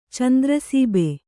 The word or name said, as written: ಚಂದ್ರಸೀಬೆ